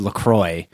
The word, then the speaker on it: LaCroix: So the thing with that is apparently it's actually pronounced LaCroix because it's like a river in like Minnesota or Wisconsin or something.